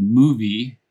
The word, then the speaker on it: Mouvi: is Samuel has a site that he created called Movie, although it is spelled differently, M-O-U-V-I.